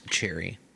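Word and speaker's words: Cherryh: She appended a silent H to her real name because her first editor felt that Cherry sounded too much like a romance writer.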